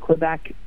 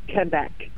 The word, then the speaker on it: Quebec: But the problem is, like, I live in Quebec, right? Which you can't call it Quebec. It's Quebec. – Sounds hideous. – Because, like, apparently Q-U-E is pronounced K.